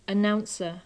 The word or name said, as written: announcer